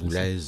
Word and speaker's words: Boulez: Boulez ou Boulez, qu'on prononce? – Boulez. – Boulez, hein, ouais.